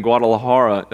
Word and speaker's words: Guadalajara: And so that he is a missionary there in that organization, and he'll be playing ball this season in Guadalajara. Did I say that right? Sounds right.